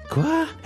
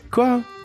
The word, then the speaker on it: quoi: Quoi? Is that how you say it in French? What? – Yeah. Quoi? – Quoi?